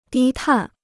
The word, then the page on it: 低碳: dī tàn